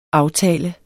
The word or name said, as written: aftalt